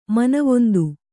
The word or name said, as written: ಮಾನವಂತೆ